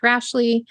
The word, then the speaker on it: Rashleigh: You can just search by my name, which is Beth Rashley, spelled like Ashley with an R in front of it. So not as complicated as it seems like.